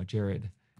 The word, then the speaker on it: Jarrad: Um, but uh, so when I was little, I couldn't pronounce my name correctly, you know, Jarrad, and so I called myself J A.